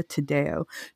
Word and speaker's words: Tadeo: Jericho, did I say your last name right? Sorry. – You did, yes. You did. You did. – Okay. Thank you. – Curiously, I meant to ask you ahead of time. – No, because like that's the actual pronunciation. But my dad, weirdly, he always said Tadeo, which I thought was weird because didn't like this come from you? Thing? I don't know. But yeah, you said it right.